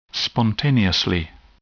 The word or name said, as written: spontaneously